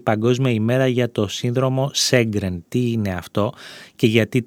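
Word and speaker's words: Sjögren: Και μια και το αναφέρουμε, γιατί και εμείς δεν γνωρίζουμε πολλά για αυτό, το σύνδρομο Σέγκρεν πήρε το όνομά του από τον Σουηδό οφθαλμίατρο Χένρινγκ Σέγκρεν, στα σουηδικά προφέρεται Γέγκρεν,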